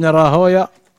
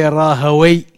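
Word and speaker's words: Rahawayh: There's a difference of opinion between the scholars whether to pronounce these names as an A sound at the end or to pronounce it as an O sound. Rahaway or Rahoya.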